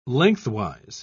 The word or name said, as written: lengthwise